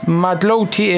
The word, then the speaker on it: Mathlouthi: Se escribe Emel, como suena, E-M-E-L. Emel. Y el apellido es Matlouti, es M-A-T-H-L-O-U-T-H-I.